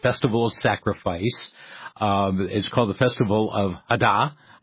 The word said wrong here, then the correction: Eid al-Adha: You've got a different pronunciation, I know. – Eid al-Adha.